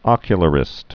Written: ocularists

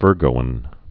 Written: Virgoan

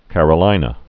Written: Carolina yellow jasmine

